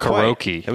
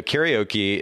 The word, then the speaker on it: karaoke: So everybody says karaoke. Do you have any idea how you actually pronounce the word karaoke? – Karate-oke. – No, not karaoke. It was very, very close, but not quite. No, karaoke is actually pronounced karaoke.